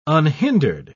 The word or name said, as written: unhindered